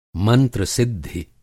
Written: मंत्र-सिद्धि

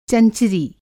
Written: चंचरी

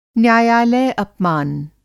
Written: न्यायालय-अपमान

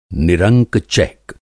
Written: निरंक-चैक

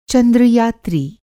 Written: चंद्र-यात्री

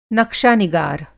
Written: नक़्शानिगार

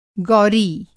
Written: गौरी

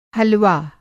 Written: हलवा

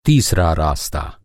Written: रास्ता

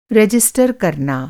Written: रजिस्टर

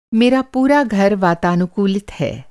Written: वातानुकूलित

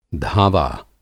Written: धावा

